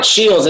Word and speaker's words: Sheils: You got it, Sheils. It sounds like Brooke Sheils, but there's no D.